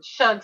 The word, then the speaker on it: Schug: I'm I'm probably going to say this wrong, but it's called Schug, S C H U G.